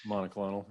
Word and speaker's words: monoclonal: And I know, how do you say that? Monocloid? I can never these medical terms. This new treatment that Governor DeSantis has ordered. – Monoclonal. – Monoclonal, the therapies for that.